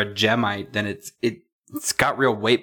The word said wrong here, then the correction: Jadeite: Is it Gemite? Did I get the name right? – Jadeite.